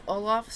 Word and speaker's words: Olaf: Meter grabbed an Olaf. Is that how you say it? – Mm-hmm. Oh, okay. – Olaf stuffed toy and proceeded to perform...